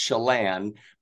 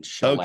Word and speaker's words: Chelan: It's pronounced Chelan, but we've been called Chelan, which I like Chelan. That kind of has a nice smooth to it, but it's actually pronounced as if you spelled it S-H-E-L-A-N, Chelan.